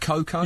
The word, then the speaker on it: Cocco: Francesco Cocco. He's coming out of retirement. He's 32. – They're not pronounced Coco? – Yeah. Coco, sorry.